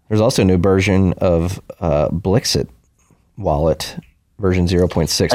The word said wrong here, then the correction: Blixt: I think it's pronounced Blixt. – Blixt, excuse me.